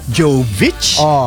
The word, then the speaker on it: Jovic: It's Jovic. – Not Jovic. It's not Jovic. It's Jovic.